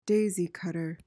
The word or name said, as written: daisy cutter